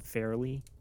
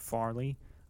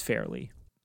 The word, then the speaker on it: Fairley: Also, I will try to be better at more consistently pronouncing the name Fairley instead of Farley. I think I get thrown off when in the British accent where there's a high stress on the ah sound when you see an A, and so that's what I do. But yeah, it's Fairley.